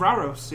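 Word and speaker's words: Rauros: would be Fall of Raros or Ruros, or how would you say that? – Fall of Raros, yeah.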